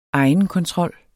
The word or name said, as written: egenkontrol